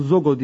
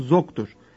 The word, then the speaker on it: Zog: Kral Zogo diye kimse yok. Yanlış olarak Kral Zogo diyoruz. Adı Zog'dur. Arnavutça söylenişte Zogo Zog diye okunuyor.